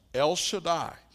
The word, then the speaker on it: El Shaddai: You see, this name for God Almighty, you may know, made popular maybe 20 years ago now, 25, by a song, El Shaddai. That name Almighty, that's how you say it in the Hebrew.